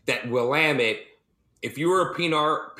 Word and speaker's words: Willamette: So if you ever want to know how to pronounce Willamette, it's Willamette.